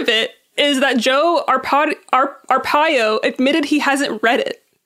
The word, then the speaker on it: Arpaio: Arpaio.